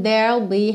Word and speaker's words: there'll: There will be, contraído, ficou there'll be. A gente vai do R direto pro L, porque there não tem o E final pronunciado, certo? Então, there, eu tô com R enrolado, eu já vou pro L da contração do will, ok? There'll be.